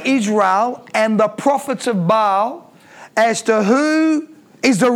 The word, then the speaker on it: Baal: It's actually pronounced Baal, but we'll call them prophets of Baal.